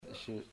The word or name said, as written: Yshir